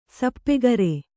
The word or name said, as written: ಸಾಪುಗಾರ